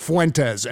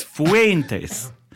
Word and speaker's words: Fuentes: The first syllable's got to rhyme with hey. It's Fuentes.